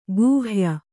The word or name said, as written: ಕೈಹೊಯ್